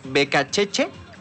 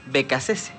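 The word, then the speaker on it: Beccacece: Puede pronunciarse como Becacheche o Becacese. Está bien de las dos formas.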